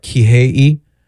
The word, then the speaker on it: Kihei: Kihei.